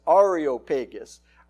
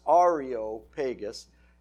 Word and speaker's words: Areopagus: And the correct pronunciation, and I'll qualify that in a minute, is Areopagus. I was reading A-E-R-O, which would be Areo, Areo, but it's not. It's Areopagus.